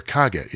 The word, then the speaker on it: Kage: By the way, apparently Kage is not pronounced Cage, but rather Kage.